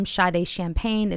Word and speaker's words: Sade Champagne: Instagram, I am Sade Champagne. It's spelled S-A-D-E and then champagne like the drink.